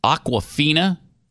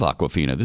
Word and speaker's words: Awkwafina: Isn't that a water bottle? – Yeah, that's a popular brand of liquid beverage. – I thought so. Mm-hmm. I love Awkwafina. This is spelled a little different than the water bottle. But Awkwafina.